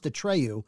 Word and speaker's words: Atreyu: It's pronounced Atreyu. Atreyu.